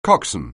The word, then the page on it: coxswain: anglais